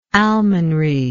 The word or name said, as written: almonry